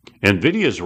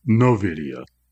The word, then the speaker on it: Nvidia: how to say its name. NVIDIA, not NOVIDIA.